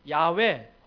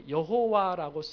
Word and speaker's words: 야훼: 우리 성경에 여호와라고 쓰여져 있는 그 발음은 잘못된 발음이고 야훼 혹은 야훼일 거라고 학자들이 이야기를 하는데 그런데 그것도 몰라요.